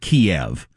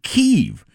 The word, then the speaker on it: Kyiv: But I heard a Ukrainian explain that Russia pronounces it Kiev, but Ukrainians have always called it Kyiv.